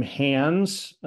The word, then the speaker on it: Hands: I don't think you're going to run into a lot of people with the last name Hands. It's spelled just like it sounds, H-A-N-D-S, David Hands.